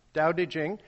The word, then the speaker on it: Tao Te Ching: The Tao Te Ching, pronounced with a D but spelt with T's, Tao Te Ching, is the founding text of Taoism.